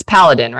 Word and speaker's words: Paladin: It's Paladin, right? That's how you say it? – Yeah, the Paladin group of companies, Paladin Security, which many people in the Canadian market are very familiar with.